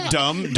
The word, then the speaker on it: dumb: Yeah, the word dumb. Dumb is a silent B.